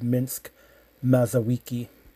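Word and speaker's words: Mińsk Mazowiecki: maybe 2 kilometers outside of Minsk, Mazowiecki, that's how you pronounce that.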